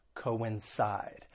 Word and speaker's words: coincide: For pronunciation, the verb has three syllables. The stress is on that third syllable, coincide. And just keep in mind the C-O-I-N, it's not pronounced as coin, two different syllables, co-in, coincide, to coincide with something.